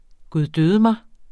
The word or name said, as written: guddødemig